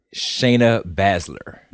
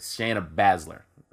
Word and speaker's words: Baszler: Shayna Baszler. – There, we go. Shayna Baszler.